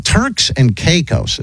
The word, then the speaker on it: Turks and Caicos: Turks and Caicos.